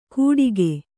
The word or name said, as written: ಗುಟಿಕೆ